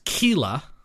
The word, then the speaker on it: chelae: They live in seaside crags and coves where they use their specialized I wanted to say chele, but I don't think that's right. – Chele. Googling. – C-H-E-L-A-E. – Apparently the singular is pronounced kela.